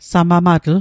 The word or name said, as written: samamatl